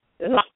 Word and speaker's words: loch: And actually, you're supposed to say it like this, lochs. You got to have that. You should have that throat thing in the back, lochs. I can't do it very well.